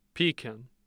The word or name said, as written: pekin